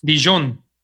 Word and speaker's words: Dijon: Paris Saint Germain versus, ¿cómo se pronuncia esto, amigo? Dijon, Dijon tú que le hablas al francés. – Dijon.